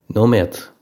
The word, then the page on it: nóiméad: Connacht/Ulster; no-may-ed; Irish